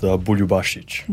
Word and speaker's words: Buljubasic: All right, so it's pronounced Buljubasic.